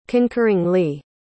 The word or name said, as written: concurringly